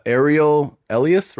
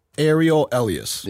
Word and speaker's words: Ariel Elias: Ariel Elias, right? Was that right? – Yes. – Oh, am I saying it correctly? – Ariel Elias. – Yeah.